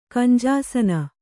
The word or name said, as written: ಕಂಜಾಸನ